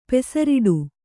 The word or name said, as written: ಪೆಸರಿಡು